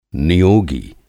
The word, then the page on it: नियोगी: Hindi